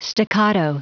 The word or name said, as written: staccato